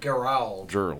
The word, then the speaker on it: Gerald: Gerald. That's a hard G.